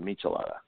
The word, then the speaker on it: Michelada: How do you say it? Michelada or Michelada? – You know, I think Bud Light makes a version, but the mixed version is a Michelada.